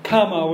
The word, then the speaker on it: kama: What I call kama manas, kama with the long A, desire, right, rather than karma.